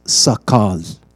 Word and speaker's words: sakal: In Hebrews, it's spelled like this: sakal, S-A-K-A-L, sakal.